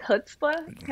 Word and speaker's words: chutzpah: No, I like chutzpah. Am I saying that right? – Yeah, pretty close.